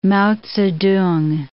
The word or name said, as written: Mao Tse-tung